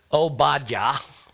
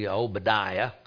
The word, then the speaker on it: Obadiah: Obadiah is kind of the way you really pronounce it, but Obadiah is the way that we do in Oklahoma.